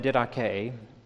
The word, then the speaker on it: Didache: It looks like the dash or something like that. It's Didache is how you pronounce it. Now, I have here an excerpt from the Didache, which is what I wrote my doctoral dissertation on.